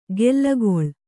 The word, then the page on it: ಗೆಲ್ಲಗೊಳ್: gellagoḷ